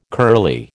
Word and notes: curly: American English

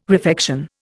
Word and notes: refection: Indian

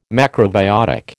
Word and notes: macrobiotic: American English

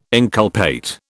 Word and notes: inculpate: British English

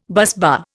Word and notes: busbar: Indian

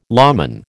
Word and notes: lawman: American English

